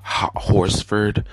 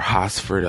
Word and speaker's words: Horsford: Her name is Tamla Horsford, or Hosford, I think is how most people pronounce it, but it's spelled H-O-R-S-F-O-R-D, and Tamla is spelled T-A-M-L-A, Tamla Horsford.